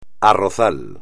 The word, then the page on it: arrozal: espagnol